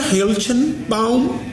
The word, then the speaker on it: Hoeltgebaum: família Hiltonbaum se escreve Hoeltgebaum, certamente um dos sobrenomes de origens germânicas mais difíceis de falar.